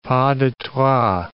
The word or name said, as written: pas de trois